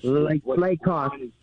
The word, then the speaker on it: Lacock: But so far, what I've found out is that one, Lacock, I think that's how you say it, dwellings, is Lacock.